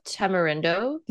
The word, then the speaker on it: Tamarindo: It's right above Tamarindo.